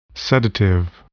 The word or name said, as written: sedative